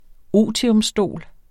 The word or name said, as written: otiumstol